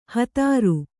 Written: ಹದರು

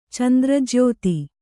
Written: ಚಂದ್ರಜ್ಯೋತಿ

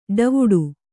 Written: ಡವುಡೆ

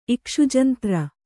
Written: ಇಕ್ಷುಜಂತ್ರ

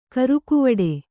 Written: ಗರಗುವಡೆ

